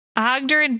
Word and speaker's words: Oggdurrinn: Ogdurin Boomsayer. How do you not know his name? – It's spelled Oggdurrinn. – No, that's why, because you wrote it dumb, and we just spelled what you said.